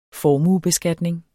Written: formuebeskatning

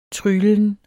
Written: tryglen

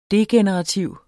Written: degenerativ

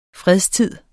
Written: fredstid

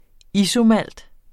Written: isomalt

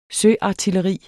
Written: søartilleri

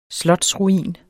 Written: slotsruin

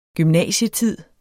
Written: gymnasietid

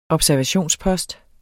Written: observationspost

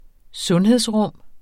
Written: sundhedsrum

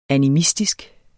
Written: animistisk

